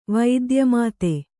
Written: ವಿದ್ಯಾಮದ

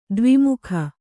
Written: ದ್ವಿಮುಖ

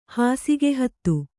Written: ಹಾಸಿಗೆಹತ್ತು

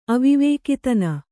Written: ಅವಿವೇಕಿತನ

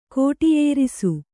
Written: ಕೋಟಿಯೇರಿಸು

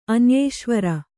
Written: ಅನ್ಯೇಶ್ವರ

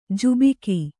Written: ಝಾಬುಕ

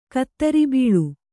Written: ಕುದುರೇಬಳ್ಳಿ

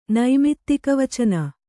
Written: ನೈಮಿತ್ತಿಕವಚನ

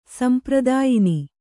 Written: ಸಂಪ್ರದಾಯಿನಿ

